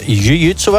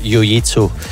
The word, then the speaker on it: jiu-jitsu: Ja, eigenlijk wordt het jiu-jitsu uitgesproken, maar in de meeste clubs in Vlaanderen zeggen ze toch wel jiu-jitsu.